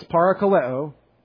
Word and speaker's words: parakaleo: That's parakaleo. Exhort. P-A-R-A-K-A-L-E-O. That's para-ka-le-o. Long O.